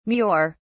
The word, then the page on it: mure: British English